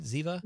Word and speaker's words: Ziva: Am I saying that right? Ziva? – You are saying that right. Yes.